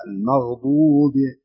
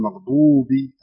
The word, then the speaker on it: المغضوب: يعني عندنا في غير المغضوب عليهم لابد أن تنطق الواء تامة، الواو، يعني ما تقول المغضوب عليهم، لا المغضوب، لا تقول مغضوبي، الواو تتمها.